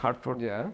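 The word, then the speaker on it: Hertford: Pero la ortografía de ese Hartford es con E en Inglaterra.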